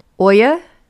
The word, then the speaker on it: Oya: In the African Yoruba religion, Oya, hopefully I'm saying that right, O-Y-A, is the goddess ruling over their fire and magic.